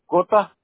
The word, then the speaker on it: Cota: Cota. C-O-T-A, Cota. – Oh, C-O-T-A.